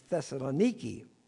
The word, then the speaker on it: Thessaloniki: We visit a Greek Orthodox church in what the Greeks pronounce as Thessaloniki. We pronounce it Thessaloniki.